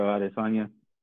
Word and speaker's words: Adesanya: Oh, Adesanya? – Is that how you say it? – Israel Adesanya.